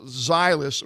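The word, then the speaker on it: Ztylus: And the gadget of the day is, I believe it's pronounced Zylus, or ZT, it's Z-T-Y-L-U-S.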